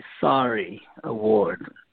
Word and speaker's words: Sarie: Well, tell me about, is it the, am I pronouncing it correctly, Sarie Award? – It's, yeah, it's S-A-R-I-E, Sarie Award.